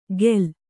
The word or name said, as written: ಖೇಲ